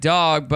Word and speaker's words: Dogue: It's pronounced like dog, but a snooty way of spelling it. D-O-G-U-E. Dog. It's like a French word.